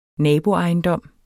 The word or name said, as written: naboejendom